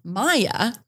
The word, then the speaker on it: Mia: And it says Mia, which is spelled M-I-A, and then has it pronounced Maya, M-Y-A.